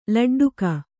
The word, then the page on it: ಲಡ್ಡಿಗೆ: kannada